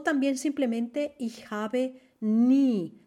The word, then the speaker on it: nie: Se escribe N-I-E. Siempre que veáis este diptongo en alemán, I-E, es una I larga, como en diesel se dice diesel. Siemens no se dice Siemens, se dice Siemens, ¿ok? La IE es siempre I larga.